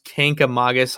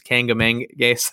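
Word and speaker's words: Kancamagus: It was right next to the, I think it's pronounced Kankamagus Highway, but everyone says Kangamangas, and it's not the right way to say it.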